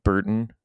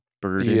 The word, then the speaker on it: Burton: No, like yeah, you know how Canadians don't pronounce T's in the middle of words? So, Burton. Burden.